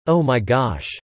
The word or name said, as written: Oh my gosh